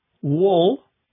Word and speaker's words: wool: For example, W-O-O-L, wool, is pronounced differently than T-O-O-L, tool. Tool is a word describing a hammer or a screwdriver, some instrument you use to fix something else. Wool and tool are spelled almost the same, but have very different pronunciations.